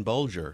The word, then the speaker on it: Bulger: It's actually two soft G's there, Berlinger and Bolger.